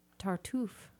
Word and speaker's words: Tartuffe: And Heartland in Bloomington Normal is doing Tartuffe. – Have we ever gotten an official pronunciation on that? – That's how you say it.